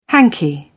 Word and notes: hanky: British English

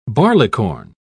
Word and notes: barleycorn: British English

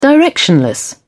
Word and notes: directionless: British English

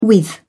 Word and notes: with: British English

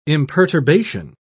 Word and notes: imperturbation: British English

